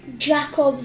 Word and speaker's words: Jakob: It would be, well, it's pronounced with a Y, but it's J-A-K-O-B. – Jakob the Ox.